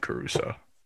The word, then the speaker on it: Caruso: Like Alex Caruso, but it's Ant Caruso.